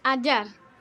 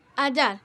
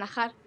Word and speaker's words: Hajar: Ayar. – Un nombre raro. ¿Cómo se escribe? ¿Lo puedes deletrear? – H-A-J-A-R. Ayar. Pero aquí en España me llaman Ajar.